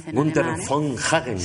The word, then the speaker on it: Günther von Hagens: Günther von Hagens.